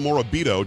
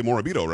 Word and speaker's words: Morabito: Her name is Angela Morabito. Did I say that right, Angela? – You nailed it. – Okay, good. I'm Italian. I mean, with Pagliarulo, I should be able to say Morabito, right?